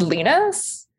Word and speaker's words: Linus: Uh, Linus Sandgren, am I saying his name right there? The cinematographer. – I think I think it's Linus.